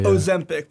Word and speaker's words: Ozempic: Ozempic. – People are taking it to lose weight. Ozempic.